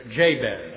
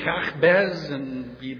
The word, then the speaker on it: Jabez: By the way, the name is pronounced Jabez. I've heard of Jabez, Jabez. In Hebrew, it would be Yahbez, but you don't need to know the Hebrew. English is good enough, so I'll stick with Jabez.